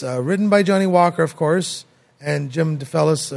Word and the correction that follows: DeFelice: DeFelice, yeah.